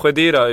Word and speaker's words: Khedira: Jag gillar också att vi fick ett korrekt uttal på eh, Kedira eller Khedira Ja, Khedira jo. Här stammar man från Mellanöstern så är det ett krav att man ska kunna det där.